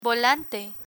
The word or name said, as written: volante